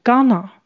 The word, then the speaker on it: gonna: going to 在口语上常常会念成 gonna。